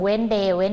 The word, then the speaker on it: Wednesday: ครูกุ้งเน้นย้ำเสียงหน่อยเพราะว่าเด็กๆออกเสียง Wednesday ไม่ได้มีตัว S อยู่ตรงกลางออกเสียงให้ชัดด้วย Wednesday